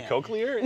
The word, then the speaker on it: cochlea: He used the British pronunciation. – It goes cochlea. – It's cochlea.